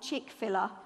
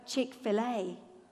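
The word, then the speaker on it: Chick-fil-A: There's a brand that I can't pronounce, but I'm reliably informed you pronounce it not Chick fil but Chick fil A.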